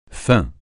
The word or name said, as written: faim